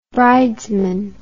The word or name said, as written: bridesman